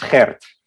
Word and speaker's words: Gert: Before we start, could you confirm how to pronounce your name, please, just so we get it correct? – Well, I listen to almost every pronunciation in the world, but I mean, correct is Gert.